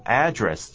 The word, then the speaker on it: address: 但是呢，在美国啊，大部分的念法呢，都念作 address，这个要特别小心，念法差很多。 address 呢，重音在第二个音节，a 呢，只念作 a 而已。但是 address 的时候呢，a 念作 a 的大的嘴型哦，然后呢，后面是轻 address，这样念法呢，有蛮大的差别。